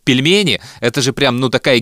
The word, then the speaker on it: пельмени: You guys keep saying, pronounce it right. Пельмени. Like peel.